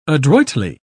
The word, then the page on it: adroitly: British English